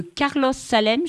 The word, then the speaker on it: Carlos Salem: Donc, vous êtes la traductrice d'un livre qui m'a beaucoup enthousiasmée, qui s'appelle « Aller simple » de Carlos Salem. Je ne sais pas si je prononce bien le mot, le nom. – Parfait, oui.